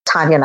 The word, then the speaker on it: Taniya: So everything on every social media, whether it's Facebook or Twitter or Instagram or my website, it's just Taniya Nayak as one word.